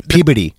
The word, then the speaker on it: Peabody: Peabody. That's how they pronounce it.